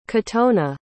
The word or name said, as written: kotone